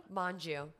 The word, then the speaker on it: Mongeau: No, it's a soft G. I know, I'm shocked. – How do you say it? Mongeau? – Mongeau or Manja.